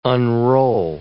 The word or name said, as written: unroll